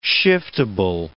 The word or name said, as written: shiftable